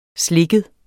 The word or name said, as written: slikket